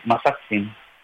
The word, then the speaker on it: Mazatzin: Okay, so once again, my name is Masak Singh. And that's spelled M-A-Z-A-T-Z-I-N.